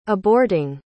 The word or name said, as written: aborting